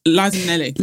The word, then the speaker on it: Minnelli: And I'm going to say her name wrong, but Liza Minnelli, isn't it? Have I said that right? – Liza Minnelli.